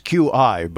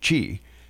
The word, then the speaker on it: Qi: See, it's QI, but it's Chinese and it's pronounced Qi. just so you know, when you hear that, or if you see QI, be the learned one and say Qi, wireless charging.